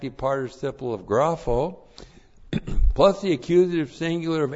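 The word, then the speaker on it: grapho (γράφω): G-R-A-P-H, long O, grapho.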